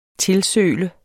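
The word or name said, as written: TILSØLET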